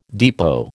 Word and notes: depot: American English

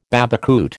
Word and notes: babacoote: American English

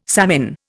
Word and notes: savin: Indian

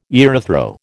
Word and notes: erythro: American English

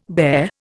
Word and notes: bare: Indian

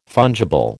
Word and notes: fungible: American English

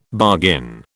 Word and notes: bargain: British English

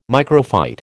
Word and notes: microphyte: American English

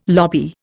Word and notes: lobby: Indian

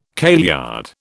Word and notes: kailyard: British English

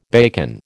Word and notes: bacon: American English

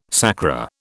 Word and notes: sacra: British English

